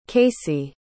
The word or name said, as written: kasie